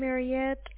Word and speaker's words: Mariette: It is spelled M-A-R-I-E-T-T. Last name initial is Samson Mike. – And reference number. – My name Mariette, M-A-R-I-E-T-T.